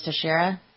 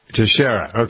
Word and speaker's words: Teixeira: Yeah, I think it was Teixeira. – Teixeira.